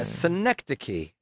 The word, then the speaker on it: synecdoche: Yeah, Synecdoche. – Anyway, it sounds like me saying it. – Yeah, it sounds like you saying Schenectady.